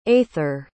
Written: aither

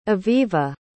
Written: avivah

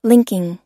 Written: linking

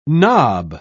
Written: knob

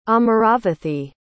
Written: amaravathi

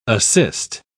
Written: assist

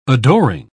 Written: adoring

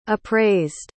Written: appraised

